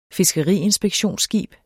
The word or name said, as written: fiskeriinspektionsskib